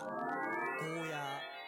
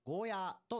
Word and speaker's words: ゴーヤー: ゴーヤ、ゴーヤ。 – よくゴーヤと言われますが、うちなんちはゴーヤーと伸ばして発音します。